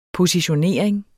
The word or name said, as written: positionering